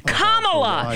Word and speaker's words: Kamala: It's Kamala! You intentionally mispronounce her name. It's Kamala. It's not that hard. It rhymes with Mamala.